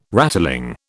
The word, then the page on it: rattling: British English